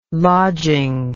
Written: lodging

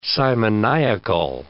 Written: simoniacal

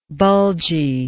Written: bulginess

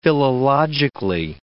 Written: philologically

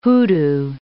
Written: hoodoo

